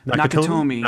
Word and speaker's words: Nakatomi: Nakatomi.